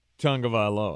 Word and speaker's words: Tagovailoa: Tagovailoa. – There you go. – Okay. – You said it correctly. I often do not. Because it's not spelled Tagovailoa.